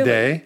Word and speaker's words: Lede: So Cliff Leddy, for those of you who don't know, it's spelled L-E-D-E. – Yes. – So first name's Cliff. – Yes. – And then L-E-D-E. Because I mispronounce it.